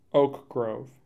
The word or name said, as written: Oak Grove